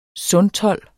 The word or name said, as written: sundtold